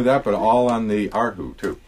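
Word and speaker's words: Arhu (二胡): In Mandarin, the pronunciation is Arhu.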